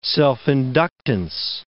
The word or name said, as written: self-inductance